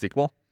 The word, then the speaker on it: SQL: So another common format that leaked and hacked data comes in is SQL databases, or SQL, pronounced SQL.